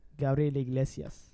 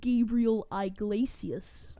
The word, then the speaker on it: Gabriel Iglesias: As Mexican people say his name... – Gabriel Iglesias. – As white people say his name, Gabriel Iglesias.